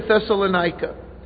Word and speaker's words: Thessalonica: It's Amphipolis, Apollonia, and Thessalonica.